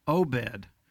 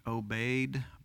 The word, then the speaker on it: Obed: Probably in, in the Hebrew pronunciation, it should be obeyed, but since we have known him for years and years and years as Obed. That's what I'm going to call him today.